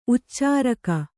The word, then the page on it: ಉಚ್ಚೆರೋಗ: uccerōga